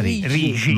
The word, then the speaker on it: Rixi: Certo, vabbè, noi comunichiamo al mondo che Rixi si legge Rigi.